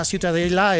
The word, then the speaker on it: Ciutadella: Para mí la Ciutadella es...